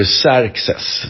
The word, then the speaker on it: Xerxes: Jag vill bara säga att eh, man uttalar ju Xerxes då.